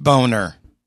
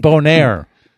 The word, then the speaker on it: Bonaire: It's not pronounced Boner. – No, I said Bonaire.